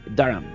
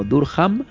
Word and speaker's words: Durham: Y allí van a construir una de las mayores joyas de la arquitectura románica, que es la Catedral de Durham. Así es como lo pronuncian los ingleses. En español lo pronunciaríamos algo así como Durham, en Inglaterra, en el norte de Inglaterra.